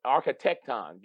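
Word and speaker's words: architekton: Is the word architecton.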